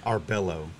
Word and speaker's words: Arballo: Arballo.